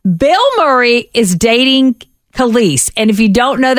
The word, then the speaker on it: Kelis: Kelis. – I don't know why I have so much trouble with that. I want to call her Kellis every time.